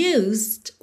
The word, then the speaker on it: used: que é o seguinte: used, o E não é pronunciado, ok? E o D, que é a consoante oclusiva, eu já ligo no T do to, então você ouve mesmo "used to", "used to", ok?